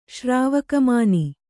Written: ಶ್ರಾವಕಮಾನಿ

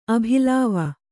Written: ಅಭಿಲಾವ

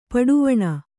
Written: ಪಟ್ಟವಣೆ

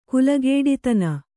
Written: ಕುಲಗೇಡಿತನ

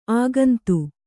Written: ಅಗುಂತಿ

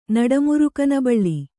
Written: ನಡಮುರುಕನಬಳ್ಳಿ

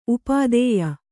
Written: ಉಪಾಧ್ಯ

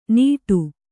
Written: ನೀಡು

